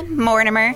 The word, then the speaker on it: Mortimer: Today, my guest is Gina Mortimer. Did I pronounce your last name? – You did actually pronounce it really well. Everyone's like, more timer.